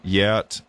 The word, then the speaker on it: Geat: By the way, you notice Gauti and Yat, which is spelled G-E-A-T, look really, really similar.